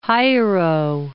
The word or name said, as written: Hiero I